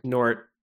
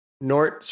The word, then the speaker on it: Gnort: Gnort. – Mm-hmm. – Or maybe it's Nort. I'm not exactly sure how you pronounce it. Yeah, Nort. – Nort.